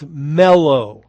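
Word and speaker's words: mellow (μέλλω): This is a Greek word that we could probably translate if we were to try and pronounce it as mellow. Mellow.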